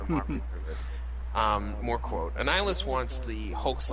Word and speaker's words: Annihilus: Yeah, Annihilus.